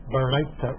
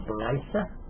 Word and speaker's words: Baraita: You'll see them, the art scroll uses the Ashkenazi pronunciation of Hebrew, so they call it a Baraita and Baraitas. I'm using the Sephardi pronunciation of Baraita and Baraitot.